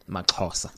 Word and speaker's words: MAXHOSA: So talk to us about the story about how it is that MAXHOSA, did I say it right? – MAXHOSA. – MAXHOSA. – Yeah. – Sounds better. – Almost getting there.